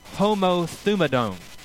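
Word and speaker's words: homothumadone (ὁμοθυμαδόν): The word that was used here is pronounced homothumadone.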